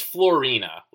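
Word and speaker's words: Fiorina: I believe it's Florina, like Florina. So it's like an Italian name.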